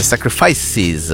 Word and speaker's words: Sacrifices: Questa è Sacrifice. Anzi, Sacrifices, perché è plurale. – Più sacrifici. – Oh. Vabbè, la pronuncia è questa, sennò la prof d'inglese bacchetta.